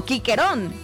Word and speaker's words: Cicerón: Y en el latín antiguo la C sonaba siempre como una K, así que el famoso Cicerón, el que el gran orador romano se pronunciaba más o menos como Quiquerón, no Cicerón.